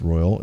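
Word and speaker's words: Royole: But a small company based in Fremont, California, named Royola, Royal, pronounced Royal, it's R-O-Y-A-L-E, is hoping to get close.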